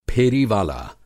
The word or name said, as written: फेरीवाला